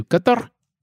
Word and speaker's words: Qatar: Karma was in turn used to reportedly surveil journalists, activists, and human rights defenders in countries including Yemen, Iran, Turkey, and Qatar, which I hear is actually pronounced closer to Qatar.